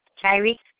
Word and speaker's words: Tyreke: T-Y-R-E-K-E. – T-Y-R? – E-K-E. – E-K-E? – Yes, ma'am. – Oh. Tariq? – Yes, ma'am. – Okay. Well, thank you, Tariq.